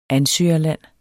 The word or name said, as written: ansøgerland